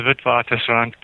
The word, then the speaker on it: Witwatersrand: The University of Witwatersrand? How would you put that yourself? – I would call it the University of the Witwatersrand, but I don't think anybody who doesn't speak Afrikaans can, can, can do better than you did.